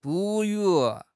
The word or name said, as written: 哺乳